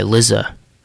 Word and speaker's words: Lizza: But yeah, Zimt by Lizza. Like lizard, but Lizza.